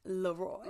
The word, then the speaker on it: Leroy: Is it Leroy or Leroy? If he was a black man, it would have been Leroy, but this white man is pronounced Leroy.